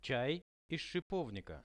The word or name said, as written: chai iz shipovnika